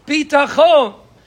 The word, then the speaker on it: Bitachon: A Bitachon is when you pronounce it with the tam milara. That means on the second syllable. Bitachon."